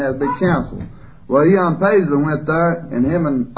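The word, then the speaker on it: Ian Paisley: Ion Paisley,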